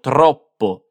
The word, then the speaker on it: troppo: Un'altra volta, quindi fai attenzione alle due P in troppo. Ero troppo ansioso.